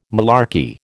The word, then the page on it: malarkey: American English